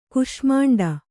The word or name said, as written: ಕೂಷ್ಮಾಂಡಿ